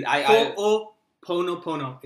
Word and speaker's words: Ho'oponopono: Ho'oponopono. It's H-O-O-P-O-N-O-P-O-N-O.